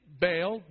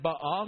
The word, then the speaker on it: Baal: We usually say Baal, but probably better pronounced Baal.